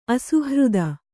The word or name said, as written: ಅಸುಹೃತ್ತು